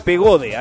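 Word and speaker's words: Pagode: La companhia de Pegado. Pegado. Pegado. Pegado. No sé cómo se pronuncia. Pegode, ahí está, muy bien.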